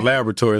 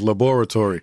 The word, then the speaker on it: laboratory: I wanted to make sure I said that right, America. It's not laboratory, it's laboratory.